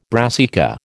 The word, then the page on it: brassica: American English